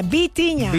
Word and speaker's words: Vitinha: No Bocinha, Vitinha.